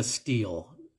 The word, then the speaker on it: stele: is from a steel, and uh, that's spelled S-T-E-L-E.